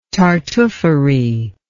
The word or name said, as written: tartuffery